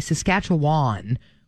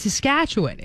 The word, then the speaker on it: Saskatchewan: Like when I grew up, I learned to say Saskatchewan with a long wan sound at the end. – Yeah. – And then I moved out there and people were like, you're saying the province wrong. It's Saskatchewan. It's kind of like a quicker ending.